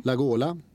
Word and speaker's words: Lagola: En svensk, Vargas och La Gola. Som det ska uttalas, har jag lärt mig, av Skavlan, La Gola, som Carola.